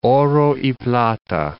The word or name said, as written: oro y plata